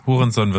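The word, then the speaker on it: Hounsou: Ansonsten Jimon Hunso heißt er, glaube ich. Ein guter Schauspieler, Hurenson. der immer mal. Hurenson wird da ausgesprochen.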